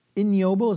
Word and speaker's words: ignoble: It is shocking that Luther's ignoble, is that how you pronounce the word? I-G-N-O-B-L-E, ignoble? – Ignoble.